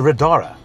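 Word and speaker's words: Radar: I'm sitting in a Radara. Is that the correct way of pronouncing it? That's correct, yeah.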